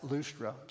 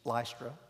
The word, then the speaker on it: Lystra: Some people pronounce it Lustra. Southern pronunciation is Lystra.